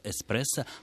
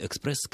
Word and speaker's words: эспрессо: И писать слово на итальянский манер, в котором невозможна комбинация звуков «кс». Да, поэтому и пишется, и произносится слово «эспрессо», хотя вы абсолютно правы, корень здесь тот самый, да, греческий по происхождению, если я правильно помню, «экспрессо» как «быстрый».